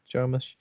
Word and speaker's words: Jarmusch: Is that how you say his name? Jarmusch? – Yeah.